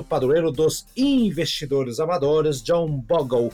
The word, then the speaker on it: Bogle: Janeiro é o mês do santo padroeiro dos investidores amadores, o John Bogle, é assim que se pronuncia? – E, John isso, Bogle. Isso, Bogle.